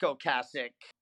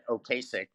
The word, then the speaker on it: Ocasek: I did get to meet Rick Ocasek, which I just learned last year. That's how you say his name. I've said it Ocasek all my whole life.